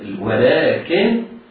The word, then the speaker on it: ولكن: وما ظلمونا ولكن كلها حدود طبيعية، ولكن كانوا عندي بعد ذلك ولكن لام ألف، يبقى لام في النطق هنا اللام بعدها ألف، وهذه الألف تمد مدا طبيعيا وإن لم تكن ثابتة في الرسم، إلا أن علماء الضبط يعني وضعوا ألفا صغيرة للدلالة عليها.